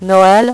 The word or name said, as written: noel